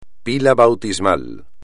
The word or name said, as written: pila bautismal